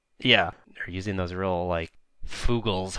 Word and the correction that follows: Fuggles: Is it pronounced Fugles? – It's Fuggles.